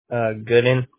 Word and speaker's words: Godin: And then Bailey is spelled B-A-I-L-E-Y. – Yep. – What's your last name, Bailey? – Gooden, G-O-D-I-N.